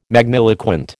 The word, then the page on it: magniloquent: American English